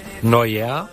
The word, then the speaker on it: Neuer: Vale, el portero se llama Neuer. – Neuer. – Neuer. – Bueno, casi. Kimmich.